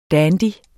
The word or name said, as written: dandy